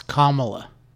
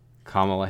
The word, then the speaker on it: Kamala: Kamala Harris, I don't know how to pronounce that. – I think it's Kamala. – Kamala Harris.